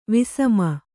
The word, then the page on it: ವಿಸಮ: visama